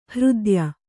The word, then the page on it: ಹೃದ್ಯ: Kannada